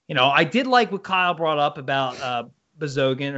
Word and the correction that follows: Bogosian: or whatever the hell his name is. But he went to Bogosian. – Yeah, thank you. He went to Tampa because they get everybody. – How did you say it? – If you look, Kelly. – Huh? – What was that again? What was his name again? – Bogosian. – There you go.